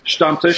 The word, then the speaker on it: Stammtisch: And am I pronouncing it correctly? – Yeah. – Because sometimes. – Stammtisch, so, yeah.